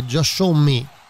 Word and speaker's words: Josh Homme: Esatto, è il 2005 e eh, i Queens of the Stone Age, eh, band di Josh Me, giusto? – Esatto.